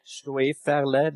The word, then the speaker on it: Stoeferle: At Ron Schwefele is my handle. I tweet about Mac. – You better spell that last name for us. – S-T-O-E-F-E-R-L-E. Schwefele, that's a South German name, actually.